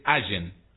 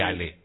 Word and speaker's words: Allen: si tenemos que pronunciar la correcta, es Allen. Llave Allen. Como, no sé, la localidad que hay en Neuquén. Allen. – Claro, exacto. – Pero claro, se pronuncia Allen con doble L. Mira vos, no es llave Ale.